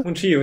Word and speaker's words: Mungiu: Christian, wie hast du ihn ausgesprochen jetzt? Mungiu, oder? – Mungiu, ja.